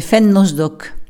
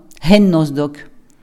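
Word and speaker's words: Fen nos d'òc: Et il n'y a pas de E en occitan. Parfois à la fin selon les parlées. Là je le prononce, je le dis Fen nos doc comme on dit à Agen. Mais si je vais à Casteljaloux par exemple, on va dire hen ou en marmande, hen nos doc.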